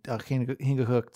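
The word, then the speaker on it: Chingachgook: Yeah, however, it's been pronounced incorrectly since his appearance in Cooper's works. It's pronounced properly, Hingwihook, the CH in initial position, resembling a guttural H. Heckewelder, a German, rendered the spelling as Cooper wrote it, but the pronunciation would be as in Heckewelder's native language.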